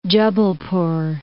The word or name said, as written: Jabalpur